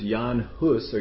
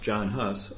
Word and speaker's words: Jan Hus: Contemporary with Wycliffe was Jan Hus, or you hear it pronounced John Hus,